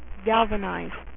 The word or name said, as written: galvatron